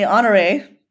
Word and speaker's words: Honore: Well, I would pronounce it normally Honore. – You would be correct.